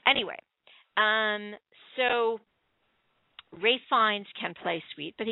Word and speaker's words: Ralph: If you like Ralph Fiennes, see, I know how to pronounce his name, which is R-A-L-P-H, but it's Ralph.